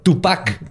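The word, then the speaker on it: Túpac: Tupac, porque tiene acento en la U.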